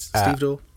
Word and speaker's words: stevedore: He was an American stevedore. Is that how you say it? – I think it is, yes. – Okay, good. – I think it is, stevedore.